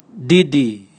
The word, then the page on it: didi: Raga